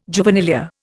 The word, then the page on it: juvenilia: Indian